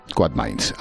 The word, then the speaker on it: Quadmins: Oye, ¿lo he dicho bien? Quadmins, ¿cómo lo digo? – Quadmins.